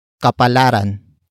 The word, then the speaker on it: kapalaran: And that actor is kapalaran. Kapalaran. Kapalaran. Kapalaran is spelled as K-A-P-A-L-A-R-A-N.